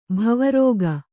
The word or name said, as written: ಭವರೋಗ